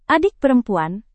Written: adik perempuan

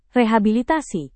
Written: rehabilitasi

